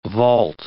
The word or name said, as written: vault